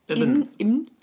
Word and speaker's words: Ibn: It's I-B-N. So I'm like, Ibn.